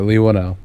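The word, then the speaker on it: Whannell: Yeah, Lee Winnell.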